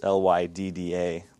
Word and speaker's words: Lydda: That's how that's pronounced, Lydda. Lydda is, but Lydda.